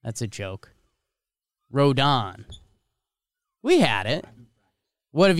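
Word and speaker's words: Rodón: I just checked the baseball reference pronunciation, and it is Rodon.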